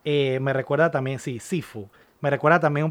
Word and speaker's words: Sifu: ¿Cómo es Sifu? – Sifu. – ¿Cómo se escribe? – Así, S-I-F-U.